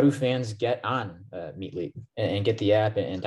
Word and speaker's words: Meetlete: So you have to go to your iPhone, go to the App Store, and download Meet League, which is spelled M-E-E-T-L-E-T-E. It's like Meet Athlete, Meet League.